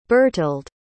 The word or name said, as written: Bertilde